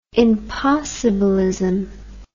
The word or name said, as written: impossibilism